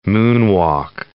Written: moonwalk

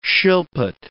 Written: shilpit

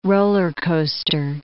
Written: roller-coaster

